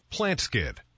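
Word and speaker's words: Plantskydd: Plant Skid. That's spelled plant, S-K-Y-D-D. Plant Skid.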